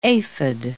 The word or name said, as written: aphid